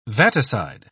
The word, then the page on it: vaticide: British English